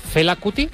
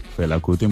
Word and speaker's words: Fela Kuti: Fela Kuti. – Fela Kuti, muy bien.